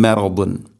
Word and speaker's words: مرض: ഫീ ഖുലൂബിഹിം മറദുൻ. അതുപോലെ തന്നെ മറദുൻ ദാ, ദാദിൻ്റെ ഉച്ചാരണം നാവിൻ്റെ ഏതെങ്കിലും ഒരു സൈഡ് അണപ്പല്ലുകളോട് ചേർന്ന് നീളത്തിൽ തട്ടിക്കൊണ്ടായിരിക്കണം അതിൻ്റെ ആ ഒരു ഉച്ചാരണം, ആ ഒരു ശബ്ദം വരേണ്ടത്. ദാ ദി ദൂ ഇപ്രകാരമായിരിക്കണം ദാദിൻ്റെ ശബ്ദം.